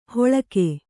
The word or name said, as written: ಹೊಳಕೆ